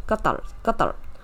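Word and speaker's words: Qatar (قطر): 当然就是说，如果你是去看它的阿拉伯文的这个原始发音的话，它是这样念哦， Qatar。那所以其实应该是卡塔尔会比较接近一点，但是就是说可能台湾这边呢是比较用英文的这个翻译过来，所以是 Qatar 这样子，就卡达。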